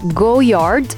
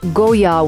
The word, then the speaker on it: Goyard: Goyard, it's written Goyard, but it's actually pronounced Goyard without the D at the end.